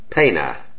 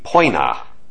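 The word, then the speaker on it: poena: O-E. Also in Church Latin, pronounced like that short E sound, E. Like in pena, P-O-E-N-A, a word meaning penalty or punishment. In classical Latin, it would be poina, that O-E sounding kind of like an O-Y.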